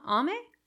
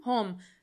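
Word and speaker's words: Jaume: Huh? Ame? No. – Home. – Yeah, that's it.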